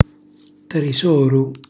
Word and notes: trisoru: Calabrese

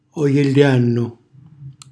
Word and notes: ojellànnu: calabrese

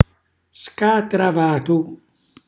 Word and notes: scatravatu: Calabrese

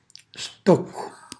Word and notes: stoccu: Calabrese

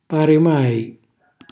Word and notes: parimai: calabrese

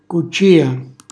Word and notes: cuccìa: calabrese

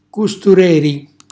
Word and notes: custureri: calabrese

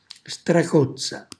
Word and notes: stracozza: Calabrese

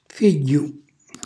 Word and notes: figghju: calabrese

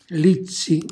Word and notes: lizzi: Calabrese

